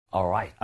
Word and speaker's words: alright: Alright 하면은 아이 뭔가 촌스러워요. Alright. – Alright. – Alright. – Alright. 그죠? 아직은 습관이 남아서 L 발음 내려고 할 수도 있는데, 다시 한번 해보세요.